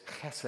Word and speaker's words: chesed: Can you say that? Chesed. Okay, you kind of almost like you're spitting. It's a chet. Chesed. The accent on the first syllable.